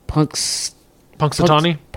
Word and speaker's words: Punxsutawney: Punxsutawney? – Yeah.